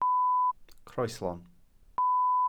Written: croeslon

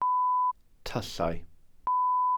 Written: tyllau

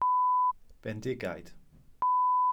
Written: bendigaid

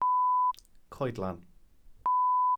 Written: coedlan

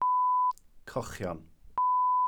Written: cochion

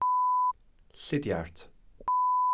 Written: llidiart

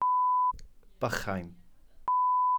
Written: bychain

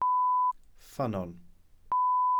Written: ffynnon

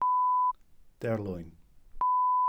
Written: derlwyn

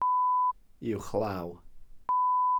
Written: uwchlaw

